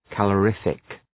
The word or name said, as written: calorific